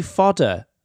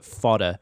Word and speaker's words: FODR: It be Fodder. That's the new band. It's F-O-D-R, but we pronounce it Fodder.